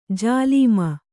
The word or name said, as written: ಜಾಲೀಮ